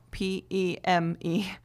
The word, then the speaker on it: Padme: And then um, for like Om Mani Padme Hum, they often pronounce Padme as Peme, like P M E no, P E M E, Peme, and then Hum, which is H U M.